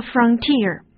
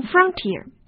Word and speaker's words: frontier: 那么说到这个 T-E-E-R 呢，那么英文里面实际上还有一个 T-I-E-R 作为一个后缀啊。你比如说边疆和边境这个词叫做 frontier。 OK, 那么大家首先要注意一下这个词的发音啊，它的重音呢在后面，而不能读成 frontier。